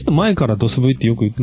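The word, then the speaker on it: DOS/V: 漢字ロムって言って売ってたりしましたよね。ドススラッシュ V と書いて、読みとしてはドス V と言いますけども、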